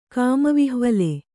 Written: ಕಾಮವಿಹ್ವಲೆ

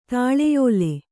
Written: ತಲೆಯೊಲೆ

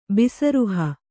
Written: ಬಿಸರುಹ

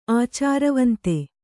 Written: ಆಚಾರವಂತೆ

